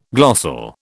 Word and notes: glossal: British English

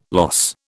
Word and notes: loss: British English

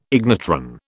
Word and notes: ignitron: British English

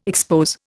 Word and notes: expose: Indian

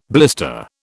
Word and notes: blister: British English

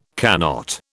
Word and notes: cannot: British English

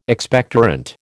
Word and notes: Expectorant: American English

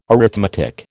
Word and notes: arithmetic: American English

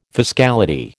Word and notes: fiscality: American English

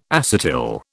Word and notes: acetyl: British English

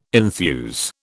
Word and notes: enthuse: British English

